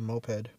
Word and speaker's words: moped: Would you rather have to drive a pink mope for the next 2 years? – Mope or moped? – Oh shoot, it probably is moped. Why is it spelled like mope?